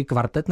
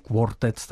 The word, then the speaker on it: kvartet: Abych to vyslovoval správně, říkáte si Krása kvartet, česky kvartet nebo anglicky quartet, takhle s tím o? – Český kvartet.